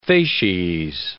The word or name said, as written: facies